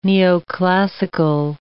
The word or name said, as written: neoclassical